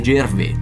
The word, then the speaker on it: Gervais: Comunque, mattatore della serata è stato il presentatore Ricky Gervais, perché è di origine francese, quindi non mi rompete le palle con la pronuncia, perché è Gervais.